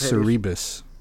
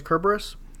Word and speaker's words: Cerberus: I always thought it was pronounced Cerebus or Cerebus. – Well, but in Greek or Latin, don't they pronounce the C as a hard C, so it's Cerberus? – I don't know.